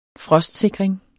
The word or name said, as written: frostsikring